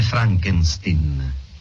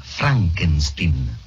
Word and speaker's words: Frankenstein: Io avrei una domanda, dottor Frankenstein. – Si legge Frankenstein. – Come, prego? – Il mio nome si pronuncia Frankenstein.